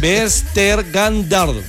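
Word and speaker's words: Vestergaard: Chabelo, lea cómo se pronuncia. – Bueno, Yannick Bers ter Gandard.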